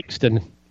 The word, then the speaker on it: Blakiston: Blackiston? Is that her name? – Blakeston. – Blakeston? There's an I in there. It's Blackiston, right? – Oh, shit. – B-L-A-K-I-S-T-O-N.